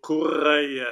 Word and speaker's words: Correa: The name is Antonio Correa. I know you got to roll those R's.